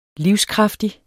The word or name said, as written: livskraftig